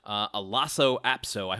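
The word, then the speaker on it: Lhasa Apso: McCormick, nicknamed Mac, Lasso Apso, I hope I'm pronouncing that properly.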